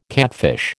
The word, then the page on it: catfish: American English